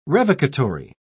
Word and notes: revocatory: British English

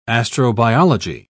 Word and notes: astrobiology: British English

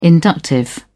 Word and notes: inductive: British English

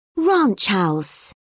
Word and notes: ranch house: British English

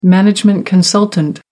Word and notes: management consultant: American English

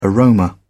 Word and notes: aroma: British English

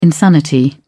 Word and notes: insanity: British English

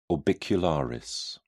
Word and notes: orbicularis: British English

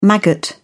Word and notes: maggot: British English

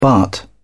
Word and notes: baht: British English

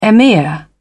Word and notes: emir: British English

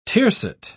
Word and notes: tiercet: British English

